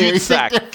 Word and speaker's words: cul-de-sac: It's cul-de-sac, and you can't tell me cul-de-sac. Cul-de-sac. It's a silent L.